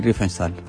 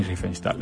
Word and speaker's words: Riefenstahl: Si le vas a decir Riefenstahl. – Yo le digo Leni Riefenstahl. – Leni Riefenstahl. Lo escuchamos. Como, acá es como le diga en la pronunciación.